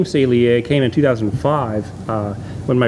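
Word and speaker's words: Saleae: So it's pronounced Salier. – Salier. I've been saying it wrong all this time.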